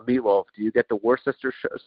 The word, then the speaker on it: Worcester: No, it's Worcester.